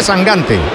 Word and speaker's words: Sangante: No, que Sangante, Sangante, se pronuncia Sangante. – Yo pronuncio como me digáis. – Bueno, eso lo he oído yo, que dice que es Sangante. – De hecho, me ha dicho, tú acuérdate de Mangante.